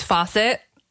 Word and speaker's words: Fawcett: I pronounce it just Fawcett. I don't know Fawcett? If that's okay. I don't know if that's the correct way, but that's how I've always said it.